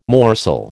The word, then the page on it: morsel: American English